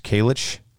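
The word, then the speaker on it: Kalisz: And the very first gold medal, and forgive me if I do not pronounce the last name correctly, it's K-A-L-I-S-Z. I believe it's Chase Kalich.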